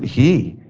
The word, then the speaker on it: He: And this is the Hebrew letter, I would pronounce it He, H-E.